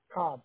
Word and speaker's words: Cobb: Cobb, C-O-B-B. – C-O-B-B as in boy? – Mm-hmm.